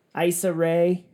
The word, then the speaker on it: Issa Rae: Issa Rae.